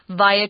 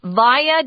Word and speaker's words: via: Sometimes I'll hear it pronounced via. Tom and I both say via. They're both correct, but I recommend via because that's what most people say.